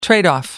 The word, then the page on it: tradeoff: British English